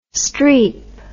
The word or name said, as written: Streep